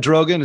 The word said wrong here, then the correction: Erdogan: Is that how I say his name? Turkish guy? – Erdogan. – Erdogan. Yeah.